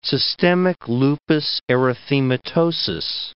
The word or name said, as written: systemic lupus erythematosus